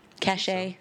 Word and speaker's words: cache: Is that how you pronounce C-A-C-H-E, cache? Cache? – Yeah.